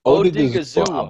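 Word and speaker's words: Odighizuwa: It's Odigazua, I believe. – Say again? Huh? – Odigazua, I think. – Yes, Odigazua, the defensive tackle out of UCLA.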